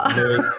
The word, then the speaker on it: Louis: And it is Louis. It's spelt Louis, but it's Louis. – Yeah, it's Louis, but Dad sometimes calls me Louis, sometimes Louis, but Mum always calls me Louis, and I think Mum sort of come up with a name.